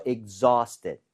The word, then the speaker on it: exhausted: AU나 AW 스펠링은 대부분 어에 가깝게 발음이 돼요. – 네. – 아우가 아니라. – 예. – So exhausted. – Exhausted. – Exhausted. 이렇게 발음을 하는 게 맞습니다.